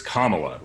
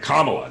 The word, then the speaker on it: Kamala: It's Kamala, right? Kamala, not any of these horrible bastardizations that you've heard from other people.